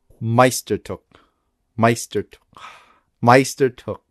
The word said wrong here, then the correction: Meisterstück: Here's how to say it. Meisterstück. Meisterstück.